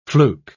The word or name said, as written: fluke